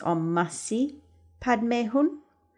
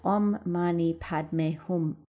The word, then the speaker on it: Om Mani Padme Hum: That's Om, O-M, Mani, M-A-N-I, Padme, P-A-D-M-E, and Hum, H-U-M. Sometimes it's pronounced Om Masi Padme Hum, but we're going to go with the Om Mani Padme Hum.